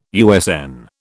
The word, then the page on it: USN: British English